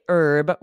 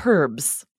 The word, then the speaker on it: herb: So in the US, we say herb with a silent H, rosemary, thyme, all of these different herbs. But in the UK, they pronounce that H and they say herbs.